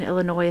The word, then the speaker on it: Illinois: The S in Illinois is silent.